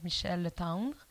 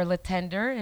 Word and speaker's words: Michel Letendre: I'm going to say it in French because it's apparently very hard to pronounce in English. My name is Michel Letendre. – Michel Letendre. – Or Letendre, if you want to say it in English.